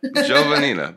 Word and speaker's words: Giovannina: You know, those Italian names, there's a lot of syllables, and everybody tries to pronounce each one. – But you could kind of smooth it out. Giovannina. – Giovannina.